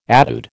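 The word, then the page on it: étude: American English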